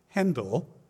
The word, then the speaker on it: Händel: So it's said that Georg Friedrich Händel, which is his birth name, Heiko and Romy would be proud of me, getting the German pronunciation correct.